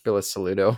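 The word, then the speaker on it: Bilusaludo: You failed the Belisarians. Again, I completely... – Belisaludo. – Belisaludos, thank you.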